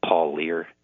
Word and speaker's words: Parlier: And I think the Ellis's last name is pronounced Paul Lear. It's spelled P-A-R-L-I-E-R.